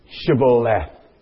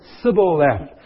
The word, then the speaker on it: Shibboleth: Pronounce Shibboleth." And those people could not pronounce their S-H's, and they said Sibboleth.